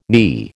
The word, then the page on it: knee: American English